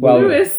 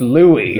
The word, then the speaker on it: Louis: And I knew that he was destined to be podcasting with us when he pronounced Louis's name as Louis Lewis. – while reading the IMDb, and Judy and I both looked up and said, "It's Louis."